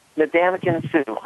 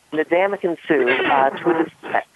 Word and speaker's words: Ndamukong Suh: His name is Ndamukong Suh. I always pronounce his first name incorrect, but anyway, Ndamukong Suh to a defensive line that already has Aaron Donald.